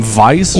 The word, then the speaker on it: Weiss: À gauche, on voit Richard Garfield et à droite, c'est Barry Waste. Barry, j'ai de la misère à prononcer son nom de famille, c'est germanophobe un peu, là. Mais c'est comme Weiss, genre, ouais. W-E-I-S-S, genre.